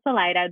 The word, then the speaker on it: Salida: Every time you said it, I was like, we should not say it like that. Like we talked about it, I remember. – Yeah, so it's called Salida.